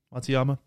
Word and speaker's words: Matsuyama: Uh, Mitsuyama, or is that how you say his name? Matsuyama.